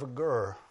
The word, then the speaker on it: Agur: Prayer of Agur, I think is how you say his name, A-G-U-R.